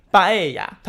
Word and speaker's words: paella: 巴艾亚，它的拼音是 P A E L L A，但是呢，L L 不发音，所以念巴艾亚。